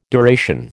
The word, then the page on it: duration: American English